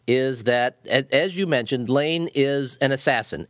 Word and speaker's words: Leine: And for people who are listening, Lane is spelled L-E-I-N-E. And I had the worst time with that. And you know, in the first book, you go into some detail about how it's pronounced, but I couldn't bring myself to pronounce it that way.